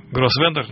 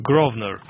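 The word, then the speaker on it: Grosvenor: Аарон, откуда-то из Бетста, не знаю, как это читается, пишет, что Гроссвендер на самом деле произносится как Гровнер.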